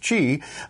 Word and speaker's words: Qi: And I'm glad you're using the term Qi, which is Qi, but it's Chinese pronounced Qi. A lot of people say, oh, I have a Qi charger.